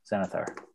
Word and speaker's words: Xanathar: Yeah, Xanathar.